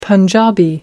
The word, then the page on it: punjabi: American English